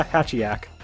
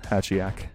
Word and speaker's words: Hatchiyack: So I Did I pronounce that correctly? Smash. – Yeah, Hatchiyack. – Hatchiyack.